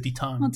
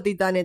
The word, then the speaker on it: Titane: Bueno, pero antes de grabar nos fijamos, chequeamos cómo se dice y se dice Titán. – Titán, sí, no sé bien. Acá nadie habla francés. – No, pero igual yo acepto Titane también.